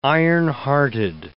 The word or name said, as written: ironhearted